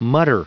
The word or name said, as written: mutter